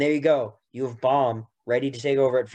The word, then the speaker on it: Bohm: you're almost making me say Baum now, because it's Baum. Derek, it's Baum.